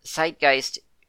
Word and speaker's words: Zeitgeist: But he says that Zeitgeist, the Z should be more like the TS in Tsunami.